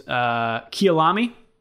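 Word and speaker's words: Kyalami: South Africa last hosted a Grand Prix at the, and I don't know how to pronounce this, Kialami track. – That's how I've always heard it.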